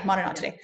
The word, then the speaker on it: Monodontidae: The Monodontidae, I think that's how you say it. Am I saying that right? Yeah, Monodontidae. – Yeah.